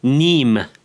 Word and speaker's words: Neame: Minu peamiseks saate külaliseks on Maik ja sinu perekonna nimi on eesti keeles kirjutatakse Neame. Kuidas sa hääldatakse? – Niim. – Niim. Maik Niim.